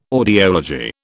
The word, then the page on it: audiology: British English